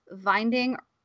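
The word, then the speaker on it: Winding: It's directed by Nicholas Vinding Refn, although it's spelled with a W, and um, he's a Danish director, I do believe, and he uh, has done a lot of movies.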